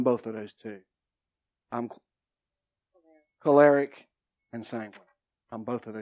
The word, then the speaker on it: choleric: Personality types, choleric. Say it again, Robin. – Choleric. – Choleric. Because it's not pronounced like it's spelled.